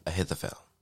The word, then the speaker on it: Ahithophel: Ahithophel.